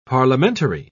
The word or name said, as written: parliamentary